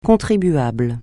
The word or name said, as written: contribuable